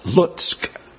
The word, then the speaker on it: Lutsk: It is pronounced Lutsk, L-U-T-S-K.